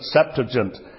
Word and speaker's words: Septuagint: We know the Old Testament is written in Hebrew, but there was a Greek version of it produced, and it's called the Septuagint, or some people pronounce it Septuagint.